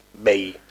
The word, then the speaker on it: bae: Y cuando una vez papáis, el crush pasa a ser tu Bay Tu Bay B-A-E. Es Bay eh, que es como bae, ¿no? Yo, yo diría bae.